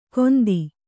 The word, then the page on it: ಗೊಂದಿ: Kannada